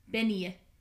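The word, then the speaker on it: bean-nighe: It's literally Bean-Negi. N-I-G-H-E. But it's Benye. Benye is how it's pronounced.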